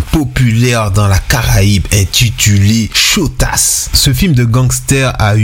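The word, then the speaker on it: Shottas: Le nom du film Shotas est prononcé phonétiquement comme chata et est devenu un terme commun dans les chansons de dancehall jamaïcain.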